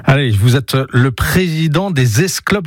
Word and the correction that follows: Esclos: je le prononce comme ça, des Esclopes Saint-Pérolais. Ça veut dire, vous m'avez dit Je rectifie, les Esclos Saint-Pérolais.